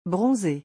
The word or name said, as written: bronzer